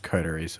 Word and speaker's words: Coteries: Vampire the Masquerade, Quarteries of New York? What? That's not a word. – It's pronounced Coteries, I'm pretty sure.